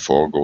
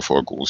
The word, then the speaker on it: Forgó: Das ist ein ungarischer Name. Ähm, man also bedeutet, das Akzent auf dem O bedeutet, dass es ungefähr gleich lang wie das erste O ausgesprochen wird. Also man kann Forgo oder Forgu sagen, das ist schon gut so.